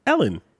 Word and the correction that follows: Elon: That's not his name. It's not how you pronounce Ellen. – It. It's Elon.